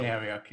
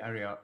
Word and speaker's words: Arioch: You say Ariok, yeah, I say Arioch.